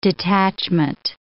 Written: detachment

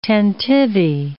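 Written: tant mieux

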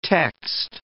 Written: text

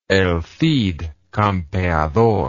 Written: El Cid Campeador

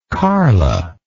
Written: carla

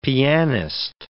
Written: pianist

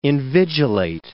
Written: invigilate

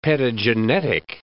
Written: pedogenetic

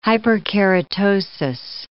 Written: hyperkeratosis

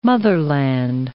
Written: motherland

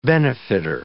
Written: benefiter

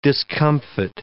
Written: discomfit

